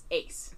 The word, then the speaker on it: Ass: so Ass in German is Ace. But it's spelled A-S-S.